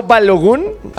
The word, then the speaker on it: Balogun: Con el tema de Flo Balogun.